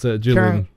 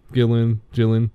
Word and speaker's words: Gillan: I don't know how you pronounce her name. It's Gillan. – Gillan.